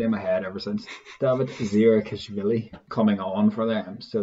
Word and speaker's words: Davit Zirakashvili: It's Rabah Slimani, who starts, and Davit Zirakashvili coming on off the bench.